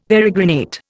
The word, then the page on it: peregrinate: Indian